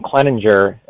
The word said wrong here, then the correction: Clendenin: I think I said Kleninger. Clendenin, isn't it? It's Clendenin. – Clendenin. Yeah, I knew I was saying that wrong.